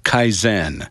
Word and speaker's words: Kaizen: from a group called Kaizen. Am I getting the pronunciation right? – Yep, that's right.